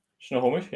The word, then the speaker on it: Snohomish: And there's a decent one, actually, I think around about, I'm probably going to butcher the name of the town, Snohomish or something. – Mm-hmm. Snohomish, yeah.